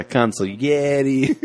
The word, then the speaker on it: consigliere: Yeah, consigliere. – Is it consigliere or is it consigliere? – Consigliere is the correct way to say it.